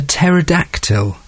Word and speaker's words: pterodactyl: you will remember I mentioned a pterodactyl. Yes, with a silent P, of course.